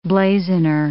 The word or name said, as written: blazoner